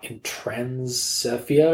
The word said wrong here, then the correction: Inostrancevia: It's Inostrancevia. At least I'm pretty sure that's how you say it.